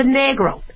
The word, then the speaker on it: negro: And you pronounce a negro. A-E sounds like A.